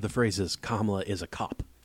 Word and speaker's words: Kamala: All the A's have the same sound. It's Kamala. You don't have to do anything.